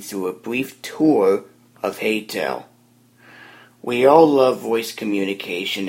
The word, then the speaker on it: Heytell: And the application I'm going to be focusing on today is called Heytel. The app name is spelled H-E-Y-T-E-L-L.